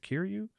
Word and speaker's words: Kiryu: Kiryu's been up to. Am I saying that correctly, by the way? Is it Kiryu? – Kiryu, yeah.